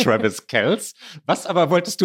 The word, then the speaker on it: Kelce: Aktuell könnten wir noch weiter über sie sprechen, das haben wir nicht getan, außer noch einmal richtig zu stellen, dass er Kelce ausgesprochen wird.